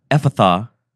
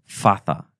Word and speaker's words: Ephphatha: Ephphatha. And it's not Ephphatha. There's two PHs in there. – Uh-huh. I know. – Ephphatha.